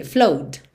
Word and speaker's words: flowed: Flowed. Você não vai dizer flowed. Esses verbos regulares terminados em ed, quase sempre esse E não é pronunciado. Então, você teria flow no presente, bota só o som do D. Flowed, flowed. Então, and the story it told of a river that flowed made me sad to think it was dead.